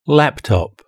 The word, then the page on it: laptop: British English